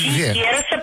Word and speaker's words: Сикейроса: Значит, ну вот Сикейроса заезжать. Сикейроса. – Через черный ход. – Да, Сикейроса 15В. Строение В. – Сикейроса, правильно говорите. Секс не по слогам.